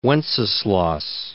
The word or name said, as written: Wenceslas